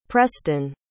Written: prestin

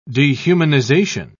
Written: dehumanization

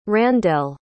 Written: Randell

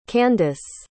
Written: candace

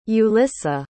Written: yulissa

